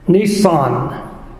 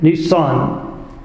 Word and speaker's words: Nisan: Chapter 2, verse 1, it came to pass in the month Nisan. Sound like a car, doesn't it? Nisan. That's the correct Hebrew pronunciation, Nisan.